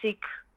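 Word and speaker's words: Szyk: En ik heb een artikel geschreven over Arthur Siek. En dat moet je, spreek je uit als chic, heb ik me laten vertellen.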